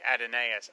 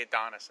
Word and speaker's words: Adonais: It's a nice little wrinkle, And I should say, you said it correctly, Adonais. I know that's how it's pronounced, but I always say Adonis in my head. I can never say that right. I don't know why. – Adonis is another guy completely.